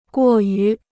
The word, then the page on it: 过于: guò yú